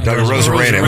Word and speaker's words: Arozarena: How do you say his last name? – Arozarena.